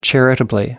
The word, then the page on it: charitably: American English